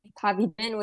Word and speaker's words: Pavi Dhiman: They're all under the name Pavi Dhiman, which is spelled out PAVI/DHIMAN.